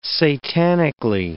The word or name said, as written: satanically